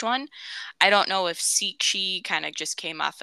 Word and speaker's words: Sica: Now, I've always heard it pronounced Sichi Hollow, but when you look it up how to pronounce it on Google, it says Sika Hollow because it is spelled S-I-C-A. But I think that's more of the phonetic sounding. However, if you look up state park information and you're reading about the state park, it has the like phonetic pronunciation and parentheses, and it says it's pronounced Shicha.